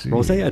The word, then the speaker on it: Rosé: But it's the Silver Rose, or Silver Rosé. Rosé, I think, is a little bit more elegant.